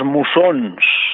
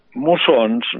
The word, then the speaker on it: mossons: I d'aquí que Joan Benegem, a Ciutadella Vea, parli dels mossons. Mossons no són més que mossens, però amb una assimilació de sa U, de sa O, però pronunciada U, inicial. Mossons, mossèn, mossons. Ja ho tenim.